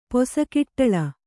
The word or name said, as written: ಪೊಸಕಿಟ್ಟಳ